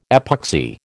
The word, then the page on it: epoxy: American English